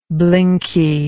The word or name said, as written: blinky